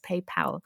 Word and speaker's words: PayPal: Also Pal ist ja der Kumpel und es wird ganz oft PayPal ausgesprochen in Deutschland, obwohl da nur ein L ist. Und das eine L sagt mir, es ist PayPal.